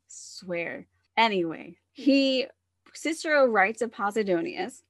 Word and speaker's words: Posidonius: And due to, now, in one of Cicero's writings, he mentions an artist called, I'm never going to get this one right, Posidonius. It's not how I keep wanting to pronounce it. I see it and I want to go Poseidon, but that's not right.